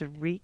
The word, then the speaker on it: wreak: Wrecking. I pronounce it wrecking havoc. – Right. It's W-R-E-A-K. – And it's, no, what is it? It's reek.